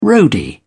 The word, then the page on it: roadie: British English